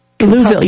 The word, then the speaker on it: Louisville: Now, Mary is a distinguished professor of women's studies at the Southern Baptist Theological Seminary in, hopefully I say this right, Louisville. – Louisville, yeah, that's how Louisville people say it.